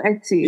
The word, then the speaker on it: Etsy: Um, I think I got it on Etsy. Etsy, Etsy how do you? I don't know how to pronounce it. – Oh, Etsy.